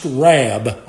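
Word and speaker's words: rab (רב): The Hebrew word that Esau used for the word enough is pronounced rab, R-A-B, and it means abundant, it means exceeding, it means plenteous, and it means sufficient.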